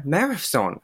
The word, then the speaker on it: Mehrafzoon: It's Tina Merifson.